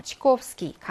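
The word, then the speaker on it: 치코옵스키 (Чайковский): 치코옵스키. 강세가 코에 있습니다.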